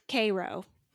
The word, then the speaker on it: Cairo: Don't even get me started on the place that looks like the capital of Egypt, but in Georgia, it's Cairo.